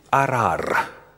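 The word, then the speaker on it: арар (אָרַר): Перед нами, во-первых, древнееврейский термин, который в оригинале звучит «арар».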